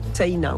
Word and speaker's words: Sena: Voilà, avec un A à la fin, Sena, on prononce le I aussi. – Comme si on avait un tréma au-dessus.